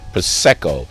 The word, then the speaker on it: prosecco: This is mostly an entertainment wine, almost like champagne or cava or prosecco, say it right, prosecco, all of those Asti Spumantes, all of those in that realm, but with a party candy taste.